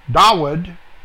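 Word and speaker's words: Dawid (דוד): This can mean both David, Dawid, is the actual pronunciation, with a waw instead of a vav, and the Messiah Yeshua.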